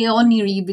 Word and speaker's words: Oneroi: So, and I'm probably going to botch the pronunciation of these, but the Caries and then the Oneroi? – Yeah, I say Oniri because it's the Greek word for them, but I'm not sure how an English speaker would pronounce it.